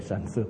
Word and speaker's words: Sun Tzu: In the natural, even the Chinese guy, Shu Zhu, I cannot pronounce his name correctly. Okay, Sun Tzu, thank you.